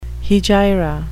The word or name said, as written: hegira